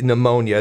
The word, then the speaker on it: pneumonia: That there's, of course, the silent P, and the E-U in the pneumonia is what gets people. So the P-N-E-U-M-O-N-I-A.